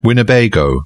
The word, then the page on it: winnebago: British English